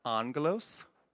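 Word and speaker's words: angelos (ἄγγελος): The word angel, by the way, in Greek, it's pronounced angelos. I've been doing some Greek study. Angelos. It's called a cognate. We've borrowed it from Greek. So our word angel is from the Greek word angelos.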